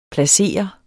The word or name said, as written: placere